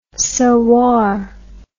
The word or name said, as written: sowar